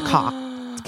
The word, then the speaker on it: Koch: But he's part of the Koch family. The Koch. K-O-C-H. – I think it's Koch. – Koch. – But they are Koch. – He pronounced it Koch, but it's Koch.